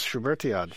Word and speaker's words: Schubertiade: A Schubertiade. Did I say that right again? – Yes, Schubertiade.